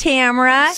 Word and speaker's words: Tamra: My name's Tamara. – Sup? – Spelled with five letters, T-A-M-R-A. Please don't put the extra A. Drives me nuts.